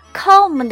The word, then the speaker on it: comb: 注意 comb 的发音，词尾需要将嘴巴闭住就好。 comb，comb，comb my hair，comb my hair。